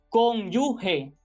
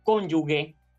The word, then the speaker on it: cónyuge: Así que para referirse a la persona unida a otra en matrimonio, lo adecuado es cónyuge, cónyuge, no cónyuge.